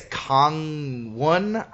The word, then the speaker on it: Gangneung: Although, yes, I did once and got the most amazing meal of my entire life, which is Gangneung. Am I saying that wrong? I'm saying it wrong. – I think it's Gangneung. The G's are pronounced like K's, which threw me off when I was trying to.